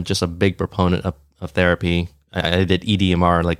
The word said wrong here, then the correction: EMDR: which I can't pronounce that anymore. It's like an eye movement, EMDR. There we go.